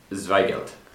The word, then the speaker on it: Zweigelt: I would say Blaufränkisch and Zweigelt. – Yes, that was much better than my pronunciation.